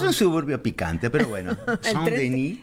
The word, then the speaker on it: Saint-Denis: Qué bien pronunciado Saint-Denis. – Saint-Denis. – Ah, perfecto.